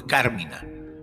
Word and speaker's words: Carmina: Aunque generalmente aparece escrito Carmina sin tilde como en el original latín, su pronunciación correcta y reincido nuevo es Carmina.